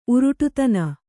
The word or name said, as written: ಉರುಟುತನ